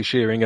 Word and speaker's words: Shearing: Well, I'm giving you Shearing as in sheep. – Yes, it is spelled like that. Yeah. But it's actually Shearing as in George.